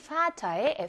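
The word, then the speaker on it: Vater: 아빠라는 의미를 지녔던 단어인 Vater의 F는 F 발음을 내게끔 되어 있습니다.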